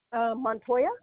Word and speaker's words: Montoya: Montoya.